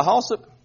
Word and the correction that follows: Hayslip: is that right? Halslip? Hayslip, would you stand and let us welcome you? Thank you very much. Hayslip. Yeah. I see Hayslip in there now.